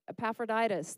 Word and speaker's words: Epaphroditus: Am I saying that right? Epaphroditus, that is, that's it.